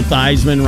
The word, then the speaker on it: Theismann: Joe Theismann rhymes with Heisman, so he changed his name.